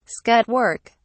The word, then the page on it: scut work: British English